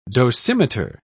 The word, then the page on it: dosimeter: British English